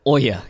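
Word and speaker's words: Ouya: What is it, the Oya.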